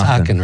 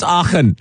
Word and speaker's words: Aachen: And how do you pronounce this? Aachen? It's Aachen, right? – We call it Aachen.